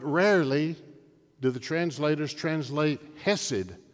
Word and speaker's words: hesed: The Old Testament word is C-H-E-S-E-D, or you make the C a silent letter, so it's H-E-S-E-D, hesed.